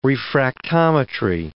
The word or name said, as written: refractometry